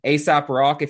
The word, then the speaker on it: Aesop Rock: ASAP Rock, if you look him up, it's spelled A-E-S-O-P, R-O-C-K. – All right, okay. – Like it's based on ASAP's Fables.